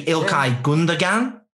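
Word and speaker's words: Gündoğan: I'd say Ilkay Gundogan. – I don't think you pronounce the G. I'm not sure.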